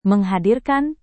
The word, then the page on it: menghadirkan: Indonesian